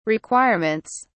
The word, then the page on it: requirements: British English